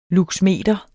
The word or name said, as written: luxmeter